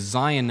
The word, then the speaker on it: Zion: And one of the parks we visited was Zion National Park, which, as a side note, is pronounced like lion, and is also another favorite rock name of mine, so much so that I named my first child after it.